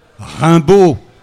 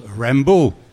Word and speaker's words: Rimbaud: Rimbaud. Et effectivement, Rimbaud, en anglais, ça se prononce Rembo, et dans l'original, il s'appelle pas, il s'appelle Rambo, R-A-M-B-O.